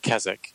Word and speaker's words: Keswick: It's named after a place in England, Keswick, and it's spelled K-E-S-W-I-C-K, but the W is silent.